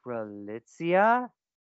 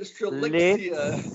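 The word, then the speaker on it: Strelitz: It's Strelitzia. Say it with me now, kids. – Don't tell us how to pronounce it. Strelitzia.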